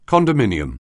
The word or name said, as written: condominium